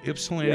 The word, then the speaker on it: Ypsilanti: Ypsilanti, yep.